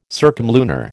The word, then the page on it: circumlunar: American English